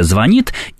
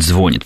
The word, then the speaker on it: звонит: Нет, ну, еще раз, да, то есть всегда во все времена существует проблема выбора между вариантами, да, то есть существует правильно классическое «звонит», и, скажу сейчас страшно, да не забросают меня филологи, и наши правнуки, скорее всего, будут говорить «звонит», потому что тенденция, к сожалению, такова, что ударение в этих глаголах смещается все-таки на корень.